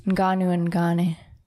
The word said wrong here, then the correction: Gane: Close. Cyril Gane. Gone.